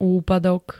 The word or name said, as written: úpadok